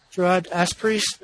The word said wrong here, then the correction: auspices: Auspices. – Auspices. – Yeah, the auspices. – Auspices. Under judicial auspices.